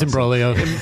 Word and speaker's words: Imbroglio: Did you like Imbroglio or Imbroglio? – I don't know how to pronounce one of them. – We pronounce it TikTok. – It's Imbroglio.